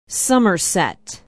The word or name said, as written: somerset